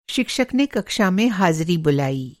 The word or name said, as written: हाज़िरी